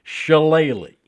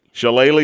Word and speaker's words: Shillelagh: It's the battle, and let me say this correctly. – Say it right. – I have to do it slowly. For the Irish Shillelagh. – Shillelagh is correct.